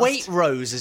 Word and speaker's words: Waitrose: We're not Waitrose as you call it. Waitrose. It has a T. Waitrose. It's not a silent T.